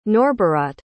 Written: norberaht

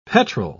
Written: petrol

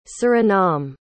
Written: Surinam

